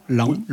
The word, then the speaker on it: Laon: Laon, oui, c'est Laon. Ça. On dit Laon, je crois, en général. Oui, oui, Laon. Ça s'écrit L-A-O-N. Mais on dit bien Laon.